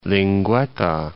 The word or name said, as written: Cape Linguetta